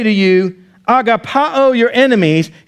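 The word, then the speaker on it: agapao (ἀγαπάω): It's the Greek word agapao, or as Americans say it, agape.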